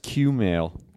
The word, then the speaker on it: Kumail: Kumail and I did a couple shows. We did shows in Portland and then Seattle. – I think it's pronounced Q-mail.